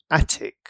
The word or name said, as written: attic